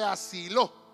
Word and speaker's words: Siló: Ese año el Cana fue asilo con su familia asiló, tiene acento en la obra, fue asiló con su familia.